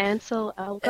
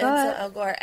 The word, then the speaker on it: Ansel Elgort: And it's cast Shailene Woodley, surprise, surprise, along with, I'm going to say, Ansel Elgort. – I think that's how you say it, Ansel Elgort. – Ansel Elgort.